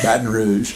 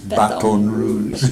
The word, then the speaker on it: Baton Rouge: Do you really pronounce it like that? – No, Baton Rouge. That's how they pronounce it down there. Baton Rouge.